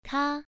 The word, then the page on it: ka: 1